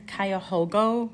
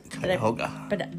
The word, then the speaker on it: Cuyahoga: So Bay Village is a city in western Cuyahoga County in Ohio. – Cuyahoga.